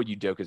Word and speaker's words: Udoka: I don't know how to pronounce it. Udoka is his last name? – Yeah, Udoka. – Emeni Udoka. – Yeah, I think that is right. I know Udoka is how you pronounce last name.